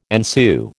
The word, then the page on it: ensue: American English